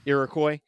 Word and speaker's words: Iroquois: Character arc is really great because he is a French knight who has an Iroquois. Is that how you pronounce it? – Iroquois with him, played by Mark Dacascos.